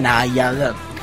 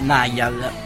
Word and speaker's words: Niall: E Giovanna che ripete è Niall, quindi Niall, chiedo scusa, ma io e le pronunce sono cose brutte.